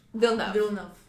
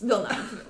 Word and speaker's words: Villeneuve: Also, I know I'm going to mispronounce his name as much as I love him, so I'm going to need some help. But Dune by Denis Villeneuve. – Villeneuve. – Okay. Two syllables. Villeneuve. Okay, got it.